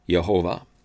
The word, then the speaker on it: Yehovah: Now Yehovah is the Hebrew name of the Lord, and most often Yehovah is translated in our English Bibles with all capital L-O-R-D. The Hebrew letters are Yod, He, Vav, He. Sometimes this is pronounced Yahweh, other times Yehovah.